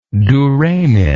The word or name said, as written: duramen